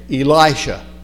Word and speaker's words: Elisha: You have to get the I to say this right. Elisha.